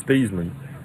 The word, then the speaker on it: Theismann: The way you pronounce his name is Thiesman, but they wanted to do a Heisman campaign for him at Notre Dame, and he became Thiesman.